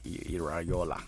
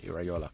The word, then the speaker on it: Iraola: Iraola. I think you say it like Iraola. – I think it's like there's a Y in there, Iraola.